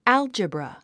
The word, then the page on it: algebra: ǽldʒəbrə; アゥジブラ